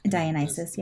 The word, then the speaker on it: Dionysus: And then that kind of splits away when we go into Greeks, which are like Aphrodite, Ares, Artemis, Athena, Dionysus. I think is how you say that one. I think. – Dionysus, yeah.